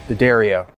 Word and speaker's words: D'Addario: I've been with D'Addario my whole career, even when I was playing bass in Van Halen, because that's what Dad would play, D'Addario for life. And I say it right, D'Addario.